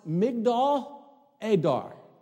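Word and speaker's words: Migdal Eder: In Hebrew, tower of the flock is pronounced Migdal Adar.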